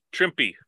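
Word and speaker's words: Trimpe: Pardon my pronunciation. Trippy. Herb, is it Trimpy? – Trimpy. – Yeah. – Herb Trimpy. Trimpy.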